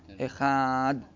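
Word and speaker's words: Echad (אחד): Ve'yarich bachet shel Echad, si prolunga un po' la pronuncia della Chet. Della Chet. Echad. La Chet.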